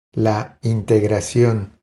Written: La integracion

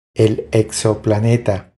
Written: El exoplaneta

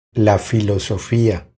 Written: La filosofia

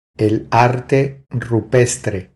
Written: El arte rupestre

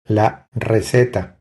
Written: La receta

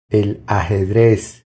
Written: El ajedrez

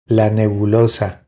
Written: La nebulosa

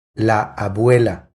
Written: La abuela

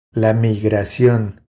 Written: La migracion

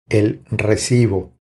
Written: El recibo

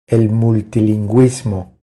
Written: El multilinguismo

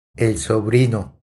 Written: El sobrino

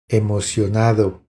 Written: Emocionado

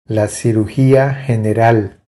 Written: La cirugia general

